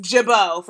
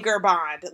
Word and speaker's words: Girbaud: A lot of them don't look anything like how they're pronounced. Jibaud, for example. That's spelled G-I-R-B-A-U-D. Like, if you don't know how to say Jibaud, like, you might say Gerbond.